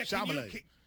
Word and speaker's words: Chalamet: Yeah, Chalamet. Can you pronounce it? – Yeah, Chalamet.